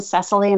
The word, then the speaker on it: Cecily: So people will say, I'll say my name, Cecily, and I usually will get some kind of fearful look back and at me.